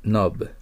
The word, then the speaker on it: knob: 次も K を発音しません。 Knob。